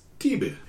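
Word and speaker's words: tebah (תבה): T-U-B-B-U. – Okay. – Okay. The Hebrew word for ark is tebah. – Huh. Interesting. – Which is T-E-V-A-H, but the V is pronounced as a B, in Hebrew.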